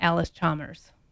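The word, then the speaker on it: Allis-Chalmers: John Howery representing John Deere and Bruce Gold representing Alice Chalmers. Did I say it right? – You said that right.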